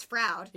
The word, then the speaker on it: Froud: Brian Froud? – Yes, Brian Froud. – Froud, Fruit, I'm not exactly sure. I think it's Froud. – It's Froud. – Yeah. – Oh. – Like insurance Froud? – Yes, insurance Froud.